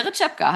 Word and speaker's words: Rytschepka: Weil dein Name wird ja ohne E geschrieben, habe ich jetzt festgestellt. – Genau, also mein Spiel ist Rytschepka. T-S-C-H wird das gesprochen.